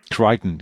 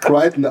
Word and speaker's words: Crichton: Crichton? – Crichton, ja, genau. – Ich glaube, da existieren so viele Fassungen, ich weiß es gar nicht. Ich sag Crichton, aber von mir aus.